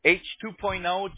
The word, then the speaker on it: H2.0: The project is called H2.0, pronounced H2 as a number 2.0.